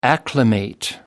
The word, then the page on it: acclimate: British English